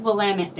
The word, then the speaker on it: Willamette: It's Willamette, damn it. I'm so sick of people saying it wrong.